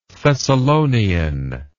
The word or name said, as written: thessalonian